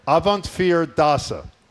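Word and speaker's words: Avondvierdaagse: So it is, I can say slowly, Avant Dachse. – Avant, what's the middle? – Avant Fear, the number four, Fear. – Avant Fear Dasa. – Dasa, yeah.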